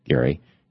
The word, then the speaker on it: Gerry: And yesterday I was apparently mispronouncing the name of Mr. Eldridge Gerry. I was calling him Mr. Gerry because they call it gerrymandering, and it's G-E-R-R-Y. But it turns out one of his heirs, I think, sent me a note. It was somebody whose last name was Gerry. Gerry, and said, no, it's pronounced like Gerry.